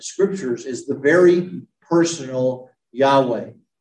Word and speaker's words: Yahweh: So the personal Yahweh, and again, there are no vowels in Hebrew. So the way that Yahweh is spelled in the Hebrew is YHWH, Yahweh. We added the vowels because we need them.